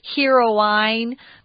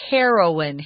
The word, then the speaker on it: heroine: And it's pronounced a little bit differently. You'd think it was heroine, but it's heroine, hair, like the hair on your head, heroine.